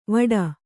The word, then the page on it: ವಡ: Kannada